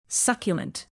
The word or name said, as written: succulent